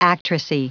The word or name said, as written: actressy